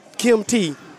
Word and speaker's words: Kim T: have Kim T. – Oh, yeah. – That almost rhymes with kimchi.